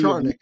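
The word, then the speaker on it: Černák: Like they sent down Cernak, who could be Charnick is how you say his name apparently.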